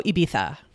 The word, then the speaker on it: Ibiza: That's how you pronounce it in Spain, Spanish. Barcelona, and also Ibiza, FYI.